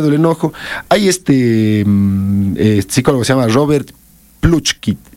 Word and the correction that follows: Plutchik: No, perdón, mi ruso, no sé, el apellido no lo pronuncio bien, es Plutchik, así.